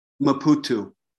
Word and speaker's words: Maputo: This is a class in the seminary in Laputo. – Correct. – How do you say the word of the name of the city? – Maputo. – Maputo. Sorry. Maputo.